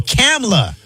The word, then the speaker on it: Kamala: Why are you adding an extra syllable, both of y'all? Kamala. – Kamala. – Not Kamala or Kamala.